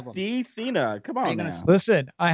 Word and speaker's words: Cena: Cena. It rhymes with peacemaker.